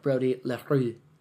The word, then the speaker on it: Brody LaRue: It's totally French because he's from Canada. Shout out to our fans in Canada. I think they say it like Brody LaRue.